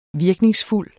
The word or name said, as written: virkningsfuld